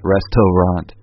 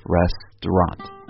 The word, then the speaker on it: restaurant: Right, like we mentioned in a previous podcast, this is one word that has three syllables, but because of the middle syllable is not important, it just kind of disappears. So it's not restaurant, it's just restaurant.